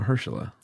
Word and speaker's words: Mahershala: Mahershala. Is that your pronounce Mahershala. – It? Mahershala. – Yeah, there you go.